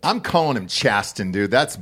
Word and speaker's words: Chasten: Is he not pronouncing the T? – Chasten is how you say that word. – Chasten. – Yeah. – It's a soft T. It's an actual word. Chasten to chasten. – Chasten is, there's no T in it, dude. – No, it's... And also, the T's kind of pronounced there. I feel like there's a T sound.